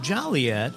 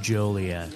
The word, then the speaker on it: Joliet: I once went on a history tour when I was in grade school where the woman said, even though it looks like Joliet and people are very jolly here, it's pronounced Joliet.